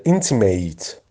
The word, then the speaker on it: intimate: اینتیمت وقتی در وقتی ادجکتیو هست تلفظش اینتیمت هست، وقتی که ورب بشه در معنای فعلیش که معنی متفاوتی هم میده کاملا، تلفظش میشه اینتیمیت. اینتیمیت فعل، اینتیمت صفت یا نام اسم.